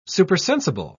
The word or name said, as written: supersensible